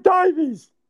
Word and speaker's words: Davies: It's pronounced as Dave Davies.